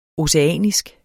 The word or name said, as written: oceanisk